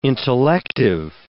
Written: intellective

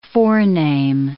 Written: forename

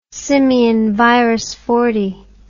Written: simian shelf

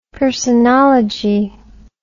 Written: personology

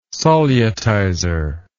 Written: solutizer

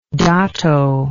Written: dato

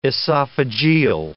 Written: esophageal

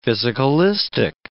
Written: physicalistic

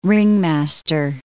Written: ringmaster

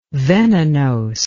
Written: venenose

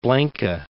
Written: Blanca Peak

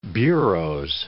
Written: bureaux

